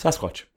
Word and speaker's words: Sasquatch: Sasquatch. Is that how you pronounce it? – Sasquatch.